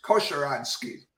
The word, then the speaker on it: Kosieradzki: Pretty close. It's Kosieradzki.